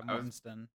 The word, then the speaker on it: Mortensen: Yeah, Mortensen.